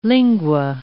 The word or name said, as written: lingua